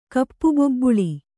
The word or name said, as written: ಕಪ್ಪುಬೊಬ್ಬುಳಿ